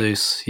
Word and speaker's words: Jesus: Gabriel Jesus, is that right? – Jesus, yes.